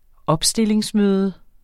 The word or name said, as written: opstillingsmøde